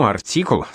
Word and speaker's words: артикул: В старину артикул с ударением на последнем слоге означал «ружейный прием».